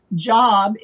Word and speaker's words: Job: Job is a Hebrew word for persecuted, though it's pronounced Job in that instance.